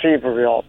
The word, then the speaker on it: Sevierville: They pronounced it, when I read the booklet I had, they said it was pronounced Seaverville.